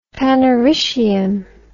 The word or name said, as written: panaritium